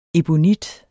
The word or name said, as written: ebonit